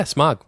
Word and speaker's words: Smaug: Smaug. Am I saying that right? – Yeah, Smaug.